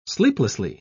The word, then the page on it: sleeplessly: British English